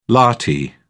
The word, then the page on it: lathi: British English